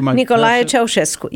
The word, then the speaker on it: Ceaușescu: Nicolae Ceaușescu, yes.